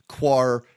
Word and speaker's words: coir: He says coconut coir. How do you pronounce coir? Is it coir? – Coir. Yeah, coir? Coconut coir.